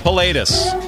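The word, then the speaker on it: Pilatus: Paletus? – Paletus.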